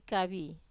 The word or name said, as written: kavi